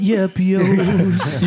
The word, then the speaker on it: Yeppios: This Honey Nut Yeppios, am I saying that right? – Yeah. – Is definitely, I've never had anything quite like this. – Honey Nut Yeppios.